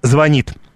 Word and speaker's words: звонит: Чем она выше, тем реже опрошенные ошибаются в ударении в слове «звонит». Для 7 из 9 граждан поставить правильное ударение на второй слог в нем труда не составило.